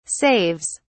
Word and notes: saves: British English

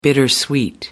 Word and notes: bittersweet: American English

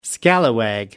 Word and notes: scallywag: American English

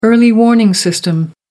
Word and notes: early warning system: American English

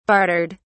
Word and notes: bartered: British English

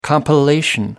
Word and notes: compilation: American English

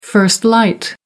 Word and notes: first light: American English

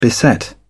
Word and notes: beset: British English

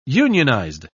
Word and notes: unionized: British English

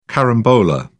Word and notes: carambola: British English

